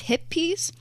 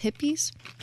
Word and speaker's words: Hippeas: We are specifically tasting is hippies. H-I-P-P-E-A-S. Hippies. Hippies. – Okay. – Sure. – Yeah, that's how you spell it.